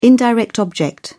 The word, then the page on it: indirect object: British English